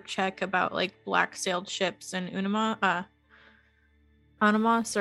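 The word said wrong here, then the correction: Annúminas: Anuminas. – How do you say it? Anuminas. Thank you. – Anuminas. It's like Minas Tirith. And then you just do Anu.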